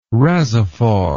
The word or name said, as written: rasophore